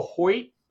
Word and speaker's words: Hoecht: Michael Hoyt, who, and it's spelled like heck, H-O-E-C-H-T, 97, has more drops in coverage than any 300-pounder in the history of the NFL, by the way.